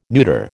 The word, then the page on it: neuter: American English